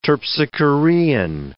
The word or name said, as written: terpsichorean